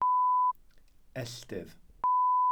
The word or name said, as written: elltydd